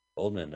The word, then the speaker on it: Oldman: How do oh. you say it? – Oldman, I would always. Oldman. Oldman. Gary Oldman.